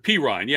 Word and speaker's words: Perine: And by the way, I thankful to a text I received because I had been mispronouncing his name last week. So I don't know where you were. – But I should have told you it's Pirine. Yeah, I didn't want to tell you.